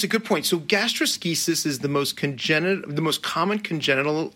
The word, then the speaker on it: gastroschisis: So this condition, gastroschisis. Did I say that right? – Said it perfectly.